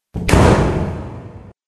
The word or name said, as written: el portazo